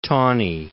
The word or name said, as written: Tawney